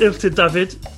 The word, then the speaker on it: Illtud Dafydd: Joining us now from Paris is Ilkhter David, have I pronounced that correctly? – Yes, well done.